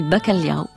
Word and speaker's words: bacalhau: Parce que morue se dit bacalhau, qu'il faut prononcer doucement en faisant couler les consonnes, comme si la saveur du mets était déjà dans le mot, comme si l'océan était toujours présent dans l'odeur des étals.